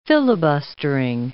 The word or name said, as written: filibustering